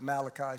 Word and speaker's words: Malachi: I think some people call it Italian, call it Malachi, but Malachi is the proper pronunciation.